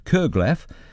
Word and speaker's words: curglaff: It's spelled C-U-R-G-L-A-F-F. Kerglaf.